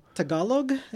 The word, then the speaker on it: Tagalog: I mean, when you can, so you can understand it's, I know how you spell, but how do you say Tagalog? – Tagalog. T-A-G-A-L-O-G.